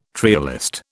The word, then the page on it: trialist: British English